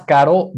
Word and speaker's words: caro: So the first one, it sounded a little bit like a D. Es más caro, but it's más caro. Soft caro. R. Caro.